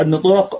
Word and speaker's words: نطاق: وشدت به البلاغة نطاقها، النطاق بكسر النون كما تقول كتاب وكتب، فهنا نطاق ونطق، نطاق جمع نطاق نطق كما تقول كتاب كتب.